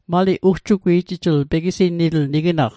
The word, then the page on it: ugjugwi'jijl: Mi'gmaq